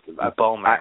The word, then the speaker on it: Bomer: I never know if it's Matt Bomer or Bomer. And I don't, so I just, Bomer. – I love him. I'm glad he's here. So Bomer, so kind of rhymes with boner.